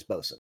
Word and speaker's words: boatswain: Boatswain is the spelling. The pronunciation is bosun. Sort of like how the forecastle is actually pronounced foxhole.